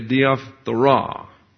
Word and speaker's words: diaphthora: The word decay is the noun diaphthora, actually diaphthora, because the accent's on the very last syllable.